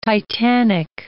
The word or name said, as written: titanic